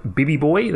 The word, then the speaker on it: Bibby Bhoy: So if you, look up Bibby Boy, that's B-I-B-B-H-O-Y. I think I've just spelt my name wrong. B-I-B-B-Y-B-H-O-Y on Twitter, or just, you know, Chris Button, whatever.